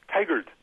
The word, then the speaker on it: Tigard: Tigard.